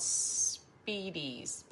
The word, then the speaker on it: spiedie: It's called Speedies, but it's spelled real weird. S-P-I-E-D-I-E.